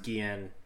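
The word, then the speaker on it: Guillén: Wait, so is it Guillen or Guillen? – People say it both ways. I think it's probably Guillen. – I'm pretty sure it's pronounced Guillen.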